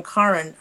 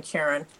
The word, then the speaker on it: Karen: I'll be talking specifically about a book called The Lost Art of Scripture, Rescuing the Sacred Texts by, and I was told she pronounced her name Karen Armstrong rather than Karen, published 2019.